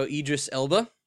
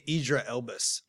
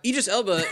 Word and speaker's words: Idris Elba: I always say Idra Elbus. I always dyslexic that. – Well, that's completely wrong. – Yeah, I know. – I usually call him Donnie Darko. Idris Elba is in talks to...